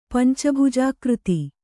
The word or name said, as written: ಪಂಚಭುಜಾಕೃತಿ